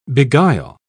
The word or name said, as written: beguile